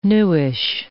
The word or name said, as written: newish